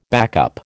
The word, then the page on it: backup: American English